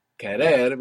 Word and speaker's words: querer: Under the stress, when we say kerer, we have the accent on the end, we have the accent on the ending.